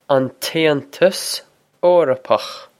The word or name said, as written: An tAontas Eorpach